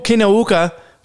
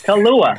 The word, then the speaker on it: Kaluuya: Kaluuya.